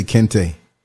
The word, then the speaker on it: Kente: It's like Clark Kent, like say Kente. Kent, like Kente.